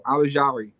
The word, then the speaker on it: Ojulari: Ojulari.